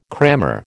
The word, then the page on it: crammer: American English